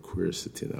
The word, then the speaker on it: quercetin: Quercetin, I always get muddled up with that pronunciation. But yeah, quercetin, Q-U-E-R-C-E-T-I-N complex.